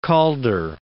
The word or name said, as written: Calder